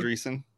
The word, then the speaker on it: Andreessen: Andreessen.